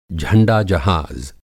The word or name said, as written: झंडा-जहाज़